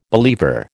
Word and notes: believer: American English

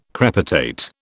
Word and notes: crepitate: British English

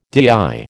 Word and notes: DIY: American English

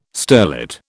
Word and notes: sterlet: British English

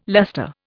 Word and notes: luster: Indian

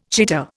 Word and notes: cheater: Indian

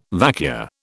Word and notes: vacua: British English

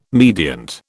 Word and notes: mediant: British English

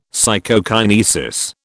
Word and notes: Psychokinesis: British English

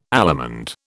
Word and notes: allemande: British English

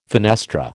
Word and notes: fenestra: American English

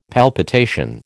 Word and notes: palpitation: American English

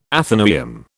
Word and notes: athenaeum: British English

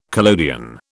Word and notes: collodion: British English

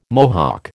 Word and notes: Mohawk: American English

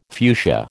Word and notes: fuchsia: American English

